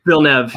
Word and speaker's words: Villeneuve: Denis Villeneuve. – That's how you say it? – Villeneuve, yeah.